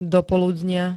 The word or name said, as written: dopoludnia